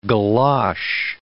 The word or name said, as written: galosh